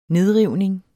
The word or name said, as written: nedrivning